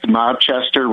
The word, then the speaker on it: Mobchester: So if you want to check out Mobchester, my podcast, Mobchester, Rhymes with Rochester.